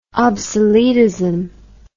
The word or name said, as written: obsoletism